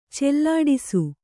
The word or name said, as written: ಚೆಲ್ಲಾಡಿಸು